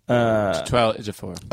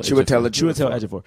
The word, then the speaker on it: Chiwetel Ejiofor: Chiwetel Ejiofor. – Yeah. – Say his first name one more time. – I think it's Chiwetel. – Chiwetel Ejiofor.